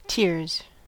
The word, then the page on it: tears: American English